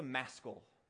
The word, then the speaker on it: maskil: Now, what's a maskil? That rhymes with rascal, by the way.